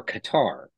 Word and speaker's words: Qatar: And instead, I ended up going from Jaburg to Qatar, which I've been saying it wrong this whole time. Like Qatar Airlines Everyone usually says Qatar. – for Qatar, right? So Qatar Airlines says Qatar like that.